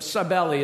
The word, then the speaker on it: Cybele: Particularly those related to Demeter and, I call her Sybil, but Cybele is probably the better pronunciation, but nonetheless, those were the main gods there in Corinth that were worshiped in a variety of ways.